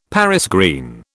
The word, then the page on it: Paris green: British English